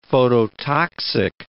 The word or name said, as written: phototoxic